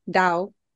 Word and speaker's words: Tao: Tao apparently is pronounced like with a D. Dao.